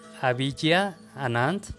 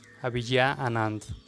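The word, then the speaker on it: Abhigya Anand: se llama Avijja Anand, se escribe A-B-H-I-G-Y-A es su nombre y A N-A-N-D, su apellido, Avijay Anand.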